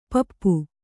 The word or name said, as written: ಪೆಪ್ಪು